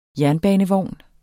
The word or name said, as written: jernbanevogn